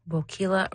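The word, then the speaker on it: Boquila: Bokula rhymes with tequila.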